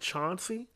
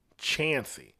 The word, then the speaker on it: Chauncey: And so, even though the name of the town was spelled like it looks like Chauncey, they pronounce it Chancey.